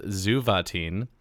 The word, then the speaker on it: Xuvetyn: So I'm just gonna plug the whole album, and I'm gonna look up how to pronounce it again, because I so it's spelled Xuvetyn, so it's pronounced Zuvatin.